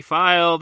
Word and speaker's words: Feil: On Twitter at Chris V File, that's FEIL, Letterboxd under the same name.